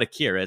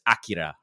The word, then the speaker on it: Akira: Like, it's not Akira, it's Akira, right? – It's Akira, yeah.